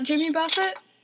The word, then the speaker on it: Jimmy Buffett: Hey, just so you know, I'm not gonna pronounce it Jimmy Buffett.